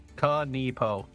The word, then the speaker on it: Kanepo: Or Kanipo. – Kanipo. – Ka with a K. – K-A-N-E-P-E-E. I have it in my notes. K-A-N-E-P-O. Kanipo.